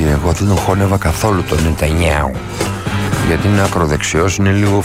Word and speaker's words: Νετανιάχου: Ο Ρετζέπ Ταϊπ Ερντοάν ζήτησε, μάλλον οι υπηρεσίες, οι δικαστικές υπηρεσίες της Τουρκίας ζήτησαν να συλληφθεί ο Νετανιάχου, ο Νετανιάου που λένε και οι Ισραηλίτες και οι Ισραηλινοί και οι Εβραίοι, και όσοι ξέρουν πώς προφέρεται το όνομα του Πρωθυπουργού του Ισραήλ, λέγεται Νετανιάου.